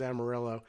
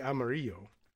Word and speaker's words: Amarillo: Also torn by the pronunciation. I guess the American Texan pronunciation, of course, is Amarillo. But it is fairly obviously Amarillo. Right, the Spanish word for yellow, but why we don't say Amarillo, I don't know.